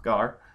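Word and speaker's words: gar (γάρ): The conjunction gar, pronounced gar, is causal.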